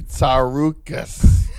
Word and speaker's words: Tsarouchas: Angelo, I apologize if I'm not saying your damn last name. – You said it right, man. – Okay. – I mean, you said it awesomely, Tsarouchas.